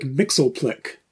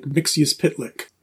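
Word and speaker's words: Mxyzptlk: But what I remember especially from the Super Friends is that they pronounced the name and they said it as like Mixleplick on Super Friends. They sort of made it into a 3-syllable Mixleplick. And then like later, I think like on the Superman animated series of the 90s, then they would really kind of stick more to the comics spelling and say like Mixius Pitlick.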